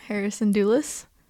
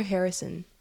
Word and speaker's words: Hera Syndulla: Hera Syndulla. – I said it right like 5 times and she still wasn't getting it. And then you called her Harrison once.